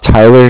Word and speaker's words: Tyler: My name is Tyler, T-Y-L-E-R, and I'm calling with Cardiff. It's spelled C-A-R-D-I-F-F, as in Frank.